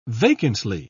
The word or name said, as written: vacantly